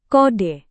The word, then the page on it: kode: Indonesian